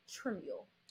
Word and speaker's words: Trimuel: She truly is an amazing soul that who is a student at the National Treasure and she is Tem I always get your last name wrong. I want to say Trimuel, but I pronounce it wrong. So it's Tamera Trimmule.